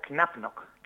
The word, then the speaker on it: KnapNok: Se pronuncia Knapnock. Knap Knock Games. Eh, eh, hay que enfatizamos mucho que la K del príncipe que hay que pronunciarla, hay que pronunciarla.